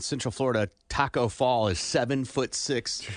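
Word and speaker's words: Tacko Fall: But there's a guy that Taco Fall, you're going to see.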